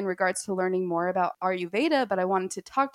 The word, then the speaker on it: Ayurveda: So Ayurveda. Like Ayur, and it means the knowledge of life. So Ayur, life, Veda, knowledge. So Ayurveda, in Hindi, it's Ayurveda because you pronounce V's like W's.